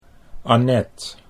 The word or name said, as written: Annet